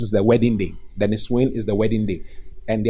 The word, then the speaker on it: Nissuin: Niswin. Niswin is spelled N-I-S-S-U-I-N. The Niswin. N-I-S-S-U-I-N. Let me spell it again. The last time someone was asking me, what's the spelling once again? It's N-I-S-S-U-I-N.